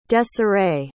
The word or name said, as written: desarae